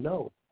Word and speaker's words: know: No. K-N-O-W. No. But damn, it gets me when you understand that the silent letter is K. It's silent.